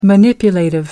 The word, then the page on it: manipulative: American English